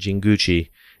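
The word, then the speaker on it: Jinguji: And my favorite role of his is Captain Jingichi. Nope, I said that wrong. Captain Jinguchi in 1963's Atragon.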